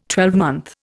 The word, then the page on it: twelvemonth: Indian